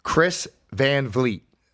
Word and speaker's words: Chris Van Vliet: Chris Van Vliet.